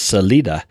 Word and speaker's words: Salida: You know, we have another town near here called Salida, which actually is Salida, which means exit. But we all say Salida because we're Yankees, you know, or that's whatever we are.